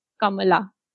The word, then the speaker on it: Kamala: It's honestly the easiest Indian name you could have, Kamala. Literally three syllables.